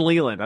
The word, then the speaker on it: Leland: It's Vreeland and Leland. I was like, it rhymes with Vreeland.